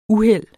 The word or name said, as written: uheld